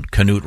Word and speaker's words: Cnut: Yeah, C, not K. – Right. – So then we were asking, was Newt Rockne Canute? We got this. – Oh, okay. – Americans pronounced the K, at least when Canute Rockne was playing. C-Nut ruled England for a time. His name was sometimes spelled C-A-N-U-T-E. So, Canute? – Yeah.